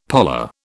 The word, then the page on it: parlour: British English